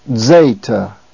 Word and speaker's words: Zeta: And the letter name, Zeta. It is the DZ sound, Z, as in the word ads. By the way, an ads is a tool, an axe-like tool for shaping wood. It's pronounced ads. The letter Zeta is pronounced like a Z with a soft D in front of it.